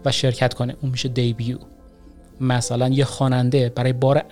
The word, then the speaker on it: debut: دیبیو چجوری اسپل میشه؟ D-E-B-U-T، یعنی آخرش یه تی داره، وسطش هم یه نداره، ولی تلفظ میشه دیبیو، D-E-B-U-T، یعنی کسی این رو ببینه میخونه دیبات، در حالی که این دیبات تلفظ نمیشه، دیبیو تلفظ میشه.